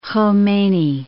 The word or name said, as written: Khomeini